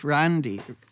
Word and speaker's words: Randi: Well, I was christened Randy, because it's a Norwegian name, so you have to figure out how to say it, you know, with an A and not an O. But I was headed off to England with the original spelling, R-A-N-D-I.